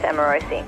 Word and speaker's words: Amorosi: My last name's always pronounced Rossi, like Emma Rossi. Which it's not. It's Amorosi.